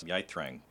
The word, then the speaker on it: Yithrang: Who is Yithrang? – That is Yithrang. – Yithrang. Thank you.